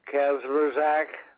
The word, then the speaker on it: Kazmierczak: Ron Kazmierczak.